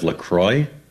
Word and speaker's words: LaCroix: You pronounce LaCroix excellently.